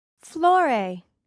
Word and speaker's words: Flore: S-A-C-H-I. – 4. – Hi, my name is Anna. What's your name? – Flore. – How do you spell that? – F-L-O-R-E.